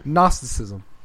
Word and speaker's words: Gnosticism: Gnosticism.